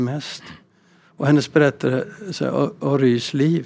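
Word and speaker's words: Rhys: Naipaul Jean Rys, man ska uttala det så, hon kom från, bägge kommer från Västindien.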